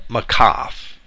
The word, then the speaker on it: maqaf: And that little dash is called a makaf. That's spelled M-A-Q-A-F.